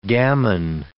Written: gammon

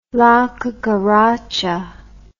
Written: La Cucaracha

